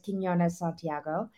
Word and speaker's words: Quinones Santiago: I know my name is very difficult to pronounce, but it is Quinones Santiago.